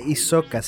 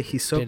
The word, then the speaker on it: Hisoka: En Japón la H muda no existe. – Entonces. Si no se pronuncia se hibiki. Se dice hibiki. – Ah, ya, perdón. Igual que no se dice isoka, se dice hisoka.